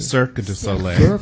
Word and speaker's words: Cirque du Soleil: Now, what was your first mask-making gig, or did you go right to Cirque du Soleil? – No, actually... – Am I pronouncing that right? Because French is lousy. – Cirque du Soleil. Circle Soleil. – Of the Sun, yes.